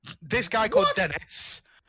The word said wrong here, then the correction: Denis: He's not called Dennis, he's called Denis! Sorry.